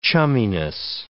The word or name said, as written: chumminess